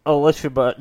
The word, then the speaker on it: Elishaba: Elishaba. No, it's like Elizabeth, but there's an S-H.